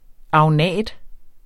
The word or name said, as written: agnat